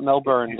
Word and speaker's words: Melbourne: Not Melbourne. They don't pronounce the R. Melbourne.